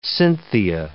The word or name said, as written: Cynthia